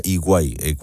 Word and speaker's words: EY: Lo voy a hablar con don José Luis Rojo, que es experto y socio de ciberseguridad de EY, se pronuncia EY, o sea, es EY,